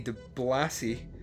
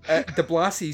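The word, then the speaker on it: DiBlasi: De Blasi is how you pronounce his surname. – Okay. – It's D-I-B-L-A-S-I, de Blasi.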